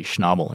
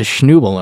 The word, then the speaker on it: Schnabel: And I go, well, it's actually supposed to be Schnabel. And she's like, ah. Well, okay, that makes sense, but what's a Schnubel?